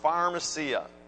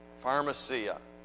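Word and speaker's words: pharmacia (φαρμακεία): And I've said it before, maybe you already know this, maybe you remember from last time I said it, but that word sorcerer in the Greek is pronounced pharmacia, pharmacia.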